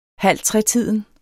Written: halvtretiden

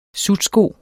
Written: SUTSKOS

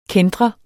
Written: kæntre

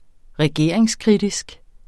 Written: regeringskritisk

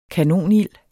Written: kanonild

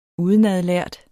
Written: udenadlært

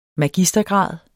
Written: magistergrad

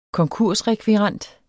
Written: konkursrekvirent

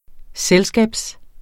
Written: selskab

